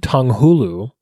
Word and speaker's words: tanghulu: It's very much like your tangulu. Am I saying that right? – Tanghulu, yeah.